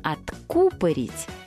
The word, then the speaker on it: откупорить: И, пожалуйста, не забудьте об ударении. Откупорить. Правильно, именно так.